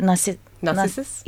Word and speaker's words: Narcissus: Narcissus? – Yeah, Narcissus.